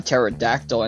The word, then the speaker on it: pterodactyl: It kind of reminds me of that other joke where, why can't you hear a pterodactyl in the bathroom? Because the P is silent. – Mm-hmm. – Because pterodactyl starts with a P. – Mm-hmm. – So, and it's also silent.